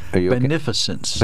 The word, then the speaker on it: beneficence: Beneficence. Beneficence. Oh, right. okay. Well, I'm making it harder than it needs to be. You okay. are. You're adding an extra syllable.